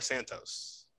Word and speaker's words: Santos: So I'm going to have to look up, is it Santo or Santos? – So it's Santo, but it's spelled Santo. So S-A-N-T-O, wait, S-A-N-T-O-S Bonacci.